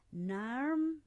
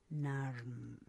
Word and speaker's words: Narrm: Está como International Women's Day 2021 NARM Melbourne. Porque en idioma aborigen, Melbourne se escribe NARRM. Eso significa Melbourne, NARM.